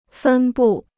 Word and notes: 分布: fēn bù

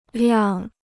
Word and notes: 辆: liàng

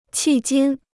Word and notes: 迄今: qì jīn

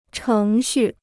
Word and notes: 程序: chéng xù

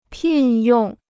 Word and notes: 聘用: pìn yòng

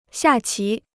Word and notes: 下棋: xià qí

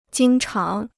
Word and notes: 经常: jīng cháng

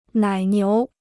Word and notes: 奶牛: nǎi niú

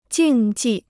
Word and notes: 竞技: jìng jì

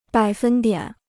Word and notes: 百分点: bǎi fēn diǎn

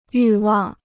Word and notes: 欲望: yù wàng

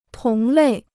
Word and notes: 同类: tóng lèi

